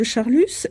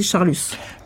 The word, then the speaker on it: Charlus: Et je crois que ce S final de Charlus, c'est aussi le stigmate de Sodome. Donc, mon hypothèse, c'est que quand Monsieur de Charlus est un Guermantes, on ne le prononce pas, et dès l'instant où il devient l'inverti qu'il est, où il apparaît dans le roman comme l'inverti qu'il est Monsieur de Charlus.